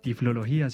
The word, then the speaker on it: tiflología: Y hoy nos acompaña como invitada Juliana Scarlett, licenciada en Educación Especial, profesional en apoyo en tiflología, ¿se dice así, Juli? – Sí, muy bien.